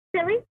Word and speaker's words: Tilly: Killy. – Killy? Mm-hmm. That's cool. How you spell it? – T-I-L-L-Y. – T-I-L-L-Y.